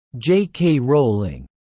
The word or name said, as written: JK Rowling